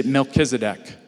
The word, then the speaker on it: Melchizedek: Say Melchizedek.